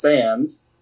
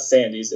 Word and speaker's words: Sandys: Right, William Sandys, 1792 to 1818, February 1874, pronounced Sands. It's not Sandys, it's just Sands.